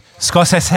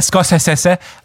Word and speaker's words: Scorsese: Ja, wie der berühmte Regisseur Martin Scorsese. Ja. – Scorsese. – Und die wenigsten wissen, dass der Scorsese ausgesprochen wird.